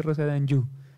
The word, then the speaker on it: Anjou: Rosé de Anjou se escribe, pero se dice Rosé de Anjou.